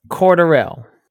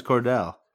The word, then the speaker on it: Cordarrelle: Cordarrelle. – Is that how it's pronounced? I thought it was Cordell. – I thought it was Cordell as well.